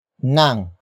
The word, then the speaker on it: nang: It is introduced by the next word, nang.